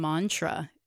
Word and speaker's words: mantra: In Sanskrit, like with the word mantra, mm-hmm. the A is a short sound. Mm-hmm. So it's not mantra. It's not, yeah, it's not even mantra, it's mantra.